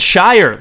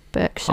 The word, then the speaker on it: Berkshire: How do you pronounce Berkshire? And I gave him alternate pronunciations, and he said Shire, like the Hobbit. – Oh my gosh, no, that's so wrong. No, nowhere in England like pronounces it like Shire. – This isn't like a regional thing. He's not like a northerner or something like that. – No, no. Like, I think everybody would say Berkshire.